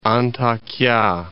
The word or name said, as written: Antakya